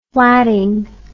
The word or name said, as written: flatting